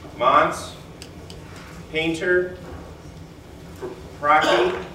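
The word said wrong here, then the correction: Paprocki: Pop Rocky, like Pop Rocks. – Pop Rocky.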